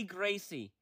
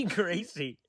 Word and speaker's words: Gracie: That's clearly Gracie. That is obviously Gracie.